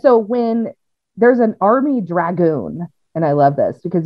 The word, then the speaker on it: dragoon: A dragoon. Yeah. Is that how you pronounce dragon? – No, you gotta add an O.